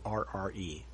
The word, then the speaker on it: Alcubierre: And I've been mispronouncing it the whole time. It's Alcubierre.